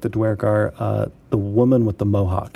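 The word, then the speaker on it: Duergar: Dwergar. I always pronounce it Dwergar. Is that wrong? – I do, too.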